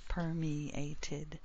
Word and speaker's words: permeated: So permit, permeate. Two syllables in permit. Three in permeate. So permeated. We need that extra syllable. Four syllables in permeated.